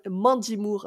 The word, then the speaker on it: Mandy Moore: C'est Ma Mandy Moore.